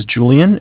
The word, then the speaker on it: Julian: Okay, my name is, my name is Julian. It's J-U-L-I-A-N. – Okay. – I'm calling with Bank of Cardiff. Cardiff is spelled C-A-R, D as in David, I-F-F as in Frank Frank.